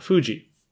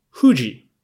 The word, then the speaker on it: Fuji: In Kunre and Nihonshiki, it's written as H-U-J-I, which is Fuji. Fuji. Now, I know neither Fuji or Fuji are actually the Japanese pronunciation, but I think the F pronunciation, Fuji, Fuji is closer to Fuji than Fuji.